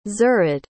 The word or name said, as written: zared